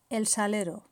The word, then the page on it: el salero: Spain